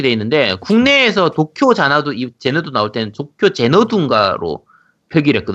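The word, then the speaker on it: Xanadu: 뭐라고 발음돼 있어요? 발음 기호상? 도원경이고 제 제너두예요. 제너두. – 아 제너두예요. 네. 아 그렇군요. 제너두라고 합니다. 그럼 제너두라고 읽겠습니다.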